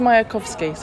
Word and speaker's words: Mayakovsky: Vladimir Mayakovsky. – Yeah, you did pronounce it incorrectly. Well, it's Mayakovsky, so almost right. You said a couple of the letters the wrong way around.